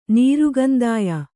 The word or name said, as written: ನೀರುಕಂದಾಯ